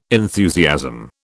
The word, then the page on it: enthusiasm: British English